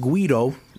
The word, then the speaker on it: güiro: It's pronounced Guido, like Guido, but it's actually spelled G-U-I-R-O, spelled like guiro or güiro.